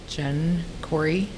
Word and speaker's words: Jenn Corry: My name is Jenn Corry, J-E-N-N, C-O-R-R-Y.